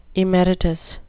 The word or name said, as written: emeritus